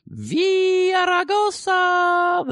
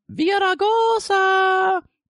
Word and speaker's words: Villaraigosa: Villaraigosa! The good mayor changed his tactics. That's the way he wants you to pronounce it, by the way. We've been over this. Villaraigosa!